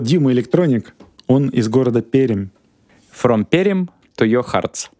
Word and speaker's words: Пермь: Неправильно говорить Пермь, правильно говорить, Сережа? Пермь. – Перим, Мягкая R, мягкая. Чуть-чуть Е можно добавить.